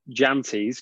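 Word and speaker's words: Jantjies: Henrik Jantes, because you actually have to pronounce the J in his name, not like Elton.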